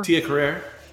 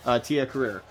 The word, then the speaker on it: Tia Carrere: Tia Carrere. – Thank you. Tia Carrere.